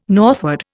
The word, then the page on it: northward: Indian